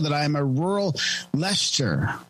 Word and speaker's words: Leicester: I'm going to have to DM him on and tell him how to Leicester. – Leicester. Just pronounce it like, it's like Uncle Fester. – Yeah. – With an L.